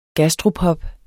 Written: gastropub

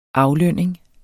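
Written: aflønning

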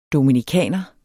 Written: dominikaner

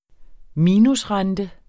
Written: minusrente

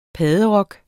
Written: padderok

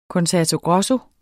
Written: concerto grosso

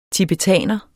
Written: tibetaner

